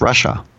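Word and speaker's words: Russia (רשע): Like at the Passover Seder, there's the son that's the Rasha, which really is pronounced Russia.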